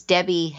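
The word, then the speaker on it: Debby: If you go to Facebook, my full name is Debbie Handrich. It's D-E-B-B-Y. And my last name is spelled just like it sounds, H-A-N-D-R-I-C-H.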